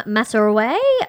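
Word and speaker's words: Maasarwe: A.R. Masoraway, I believe the pronunciation of her surname is.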